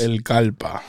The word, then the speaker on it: El Kallpa: El Kallpa.